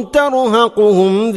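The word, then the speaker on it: തർഹഖുഹും (ترهقهم): അബ്സാറുഹും തർഹഖുഹും തർഹഖുഹും ഖാഫ്, തർഹഖുഹും അല്ല. ഓതുമ്പോൾ പലപ്പോഴും കാഫ് കൊണ്ടാണ് നമ്മൾ ഓതാറുള്ളത്. തർഹഖുഹും സില്ല, ദില്ലക്ക് പകരം പലപ്പോഴും സില്ല. ഇതൊക്കെ ശ്രദ്ധിക്കണം. തർഹഖുഹും ദില്ല.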